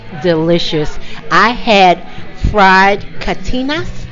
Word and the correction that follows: carnitas: Am I pronouncing carnitas? – Carnitas, yep.